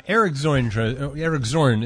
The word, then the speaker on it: Zorn: Eric Zorn. Is that how you pronounce it, Eric? I'm sorry. – That's how you say it.